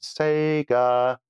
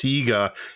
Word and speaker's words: Sega: Pajaco says, copies of Oh Mummy for the Tattung Einstein MSX and Sega Mega Drive. – No, that's not how you pronounce Sega. It's Sega. He's making a...